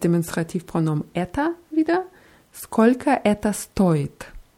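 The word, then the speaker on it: stoit (стоит): Und bei stoit ist es wichtig, die Betonung ganz deutlich auf O zu machen, weil stait heißt was anderes. Stait heißt steht. Es ist zwar aus dem Kontext klar, was man wissen will, trotzdem sollte man die Betonung beachten.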